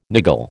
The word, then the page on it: niggle: American English